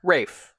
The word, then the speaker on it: Raef: Homeowner Urban Rafe, Rafe? – I don't know. How do you spell it? – R-A-E-F. – Rafe.